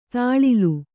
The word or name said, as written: ತಳಿಲು